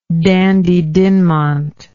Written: Dandie Dinmont

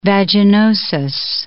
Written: vaginosis

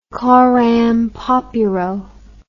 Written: coram populo

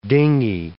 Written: dinghy

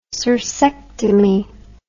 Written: cirsectomy

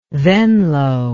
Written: venlo

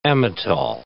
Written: Amytal

